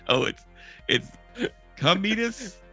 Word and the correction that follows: Cadmus: Cadmus, I'm getting the name wrong.